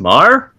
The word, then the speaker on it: Meagher: It looks like Thomas Meagher, but... – it's pronounced Marr? – Yeah.